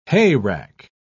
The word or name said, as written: hayrack